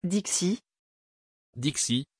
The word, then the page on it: Dixie: French